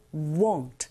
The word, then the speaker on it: won't: 이때 W-A-N-T와 W-O-N apostrophe T 이걸 구분하실 때 W-O-N apostrophe T는 won't 조금 더 동그랗게 입을 모아서 발음하시면 원하다 라는 동사 want와 구분하실 수 있어요.